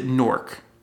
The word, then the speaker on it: Newark: I pronounce it Newark. I've been told that people not from like northern New Jersey. Pronounce it Newark. Yeah, Newark. – Newark. Not like New. Not like you're like can't say New York, but like Newark. – You have a much stronger E in there than I do. I say Newark. Almost like N-O-A-R-K.